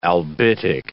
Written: albitic

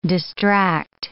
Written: distract

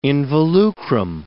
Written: involucrum

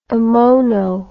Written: ammono

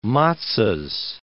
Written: matzohs

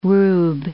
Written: rube

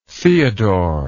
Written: theodore

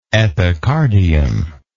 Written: epicardiac

